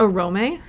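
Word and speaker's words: Oromë: The names that have, like, an E umlaut on the end, the umlaut is just there to tell you to pronounce that letter, and it's not a silent E at the end of a syllable. So instead of Orome, if you were just making the E silent, it's Orome.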